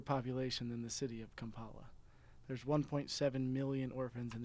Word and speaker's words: Kampala: I was doing some research as we were talking on Kampala. Am I saying that right? – Yes.